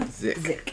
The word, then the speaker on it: Zikk: Zikk Burne. – Zikk Burne? – Zikk.